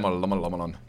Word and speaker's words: Shyamalan: Night Shyamalan, or however you pronounce his name, because I'm probably butchering that. – Shyamalan.